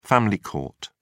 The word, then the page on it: family court: British English